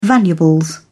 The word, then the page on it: valuables: British English